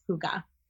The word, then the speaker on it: hygge: but before that I looked up how to pronounce it hyge, but it is hygge. – Yeah, that's the song, and Frozen is hygge, but you know it looks like hygge. – Yeah So I've been saying it wrong all this time, but according to Elsa on Broadway, it's hygge.